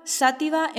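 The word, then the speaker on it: Xàtiva: Así, en muchos casos encontrarás una X que se pronuncia como la SH inglesa. Sanjenjo, Sanxenxo en Galicia, Gijón, Sison en Asturias, Játiva, Sátiva en Valencia, etc.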